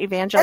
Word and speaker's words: Evangel: Is a professor emerita at Evangel. Am I saying that right? – Evangel University.